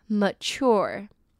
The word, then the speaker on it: mature: 再我们来看 phase, phase呢, 指的是某一个阶段, 我们通常会说 at an early phase, 就是在某一个早期的阶段, 会这样讲, 那 phase呢, ph发 f的音, se呢, 则是发z的音, phase, phase, 阶段, 最后我们来看 premature, premature呢, 指的是比预期早的, 或者是早产的, 那它就是 mature, 成熟的这个字, 再加上 pre, premature 我们来看 mature这个字它是M-A-T-U-R-E 但是跟nature这个字的发音完全不一样 M-A-T-U-R-E的M-A呢是发耳的音 mature mature OK so premature是指比预期早的或者是早产的像是a premature baby 就是早产儿那如果讲一个人很immature呢则是指他非常不成熟 premature immature mature OK, 我们最后来再来看一次例句 Genetics is in all that drives hair loss.